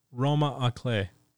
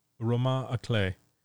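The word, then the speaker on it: roman à clef: And the Bell Jar is in book genre referred to as something that I just discovered the term for, called it's spelled Roman A Clef, and I believe it's pronounced Roma A Clef. Roma A Clef, which is a French term, which means novel with a key.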